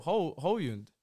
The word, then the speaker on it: Højlund: You say it correctly, but you don't say the L. – Hojlund. – Hojlund. You think it's H O L J. – Yeah. – It's H O J L.